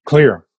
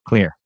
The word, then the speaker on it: Xlear: X-L-E-A-R. – X-L-E-A-R. – The pronunciation is from Finland because xylitol in Finnish is pronounced kulitol. So I took a Finnish X and made Xlear. – Love it.